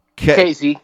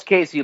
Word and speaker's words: Kaissie: Casey. – Pronounce Casey like the name, like Casey Jones. – Right. – That's how it's pronounced.